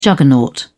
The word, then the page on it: juggernaut: British English